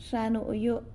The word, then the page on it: Xa nuyu: sanlucasquiavini